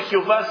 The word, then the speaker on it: Jehová: No quiere decir ahí que el nombre Jehová sea una palabra mágica. La palabra J-E-H-O-B-A, acento en la A.